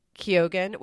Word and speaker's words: Keoghan: It is Brendan Gleeson in The Banshees of Inisherin, Brian Tyree Henry in Causeway, Judd Hirsch in The Fablemans, Barry Keoghan, which is surprising to me that that's how it's pronounced, but it is, in The Banshees of Inisherin, and Ke Kwan in Everything Everywhere All at Once.